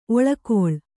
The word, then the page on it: ಒಳ್ಗಾಲ: oḷgāla